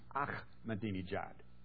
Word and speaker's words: Ahmadinejad: But the Farsi language that the Persian people speak, the people of Iran speak, it's pronounced Ahmadinejad. That's not mispronouncing it. That's his name. Ahmadinejad.